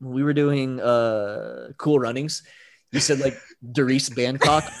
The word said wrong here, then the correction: Bannock: I'm like, his last name is Bannock.